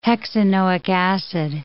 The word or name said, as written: hexanoic acid